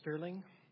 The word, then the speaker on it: Stirling: My name is Joel Sterling. My last name is spelled S-T-I-R-L-I-N-G, unlike my company name, which is S-T-E-R-L-I-N-G.